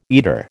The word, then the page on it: eater: American English